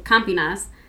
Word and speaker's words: Campinas: So on New Year's Eve 2017 in Campinas, a mid-sized city in the state of Sao Paulo, Brazil.